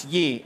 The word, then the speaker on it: Yee: Name Tim Yi. How do I spell that? Y-E-E. No, it's not we. It's Yi.